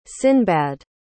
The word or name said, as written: sinbad